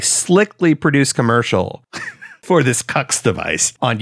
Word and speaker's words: Qux: And according to the Daily Beast, it is actually pronounced Cux. Q-U-X is pronounced Cux.